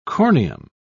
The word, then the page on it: corneum: British English